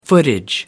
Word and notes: footage: American English

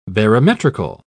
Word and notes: barometrical: British English

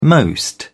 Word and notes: most: British English

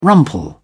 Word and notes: rumple: British English

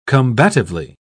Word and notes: combatively: British English

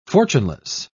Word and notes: fortuneless: British English

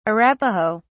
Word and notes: Arapaho: British English

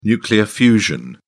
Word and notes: nuclear fusion: British English

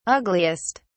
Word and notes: ugliest: British English

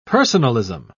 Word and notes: personalism: British English

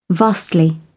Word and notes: vastly: British English

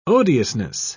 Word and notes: odiousness: British English

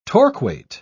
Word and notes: torquate: British English

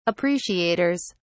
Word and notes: appreciators: British English

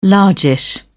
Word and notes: largish: British English

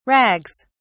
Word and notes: rags: British English

